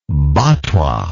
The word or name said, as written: batwa